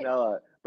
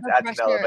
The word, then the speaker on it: Attinella: You put the N. Everyone always puts the N. Everyone always puts the N after the Attinella, right? – Attinella.